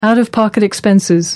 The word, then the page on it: out-of-pocket: American English